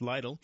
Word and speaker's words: Lidl: German grocer Lidl moving into the Apex market. The trend of grocers expanding their reach across the triangle continues. German grocer, and actually I believe it's pronounced Lidl, is expanding its footprint in the region with a store planned for Apex.